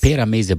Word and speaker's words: Pera Mese: Und es gibt aber seit letztem Jahr auch das Pera Mese, wie spricht man das aus? Pera Mese Bar, genau, ja.